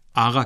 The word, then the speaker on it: آقا: لفظ قاف کو عموماً غین کی طرح تلفظ کیا جاتا ہے، خصوصاً آقا کو ہمیشہ آغا